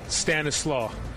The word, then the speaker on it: Stanislas: How do you pronounce it? – Stanislaw. – You don't pronounce the S? – No. – Why? – That's just how I've always heard it pronounced.